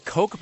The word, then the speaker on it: Koch: At a demonstration on Monday, one protester held a sign reading, "Governor Walker, kick the Koch habit." – Now, is it the Koch brothers? – Yeah, it's pronounced Koch. Okay, it's spelled K-O-C-H, but it's pronounced Koch.